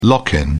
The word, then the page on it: lock in: British English